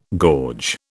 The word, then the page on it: gorge: British English